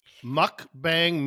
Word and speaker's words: mukbang: Mukbang median is an eating show. A mukbang, pronounced as mukbang,